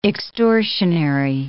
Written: extortionary

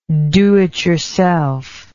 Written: do-it-yourselfism

